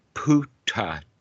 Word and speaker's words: Buddhadasa: Also, if you take a picture of Bhikkhu Buddhadasa or learn to say his name in Thai language, all the B's turn to a P and all the T's, D's turn into a P. So Buddhadasa winds up being Puttatat. – Puttatat?